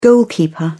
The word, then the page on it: goalkeeper: British English